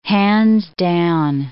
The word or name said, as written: hands down